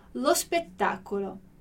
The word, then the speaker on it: lo spettacolo: Então, aqui você tem que pensar em pronunciar como se fosse uma palavra só, lo spettacolo.